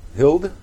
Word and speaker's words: Hild: Hild? – Hild. The E is silent.